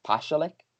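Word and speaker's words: Pašalić: Then Mario Pasalic, who apparently his name is pronounced Pasalic. He says it that way. So I'm going to have to try and start saying it like that.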